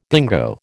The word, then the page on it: dingo: American English